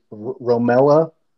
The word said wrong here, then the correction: Romelu: Lukaku. I don't know. – Romelu. – Thank you.